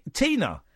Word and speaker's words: Tina: Tina.